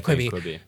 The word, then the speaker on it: Quibi: Like a 10-minute miniseries on Quibi. Like, is that how you say it? – Quibi.